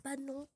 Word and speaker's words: Padnon: Enfin, le Panon Podcast, c'est comme ça que ça se prononce.